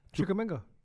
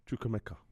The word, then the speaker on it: Chukwuemeka: Carney Chukwuemeka. – Yeah, Carney, he's very good, man. – Wait, why didn't you want to say his name? Say it. Chukwuemeka. – Chukwuemeka. – Yeah, easy. You got it, man.